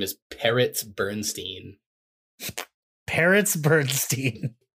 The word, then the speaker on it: Peretz: But it's like it's spelled P E R E T Z. Yeah, like Peretz. Yeah, par- but not not P A R R O T S, even though they sound the same.